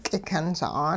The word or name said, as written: k'ikyun sa'aan